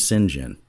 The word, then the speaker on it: St. John: One of the journalists who worked on this is St. John Barnard Smith. He'll ding me for saying his name wrong. He says his name's Sinjin, which I'm not sure how he gets to that.